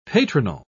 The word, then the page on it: patronal: British English